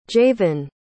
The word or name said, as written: jayvyn